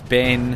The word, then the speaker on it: Ben: And my name is Ben, and rhymes with Ren.